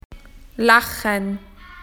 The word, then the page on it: to laugh: Australian English